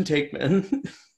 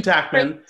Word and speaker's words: Taekman: Well, she she pronounced her name wrong. She's like, who's Kristen like Tackman? Tackman.